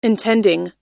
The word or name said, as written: intending